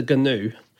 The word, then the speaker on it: Gnu: I just think Newt the Gnu is a bad character. Well, it was rejected, so that's fair enough. – That's true. – But it's not alliterative unless you don't pronounce the G in Gnu.